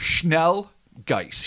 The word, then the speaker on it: Schnellgeist: So it was Schnallgeist? Schnallgeist? – I am not an expert at all on any pronunciation of even the English language, as has been well established. But yeah. – I think it's Schnallgeist.